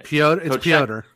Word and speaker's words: Pyotr: From what I've been told, it is no longer that Pietro, Kochekov. Russian names are not fun. Anyway, Pyotr. – So I pronounced it wrong like five times.